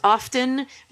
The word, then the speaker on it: often: I was told that often is the correct pronunciation, that the T in that word is silent, kind of like soften.